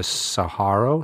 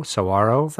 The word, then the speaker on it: Saguaro: Fantastic facility, meeting facility at the Saguaro. – Saguaro. – I always pronounce it wrong.